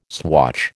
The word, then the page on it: swatch: American English